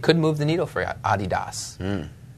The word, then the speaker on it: Adidas: Maybe they'll be able to tackle this when they come on, but Adidas.